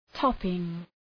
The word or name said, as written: topping